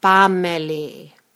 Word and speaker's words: Panmeli: When pronouncing it, you drop the N and get Pameli.